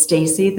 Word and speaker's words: Stacy: If people Googling, it's Stacy. The first name is spelled correctly, S-T-A-C-Y. And last name is S-H-E-W-E-Y.